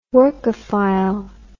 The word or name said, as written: workaphile